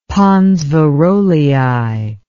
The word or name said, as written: pons varolii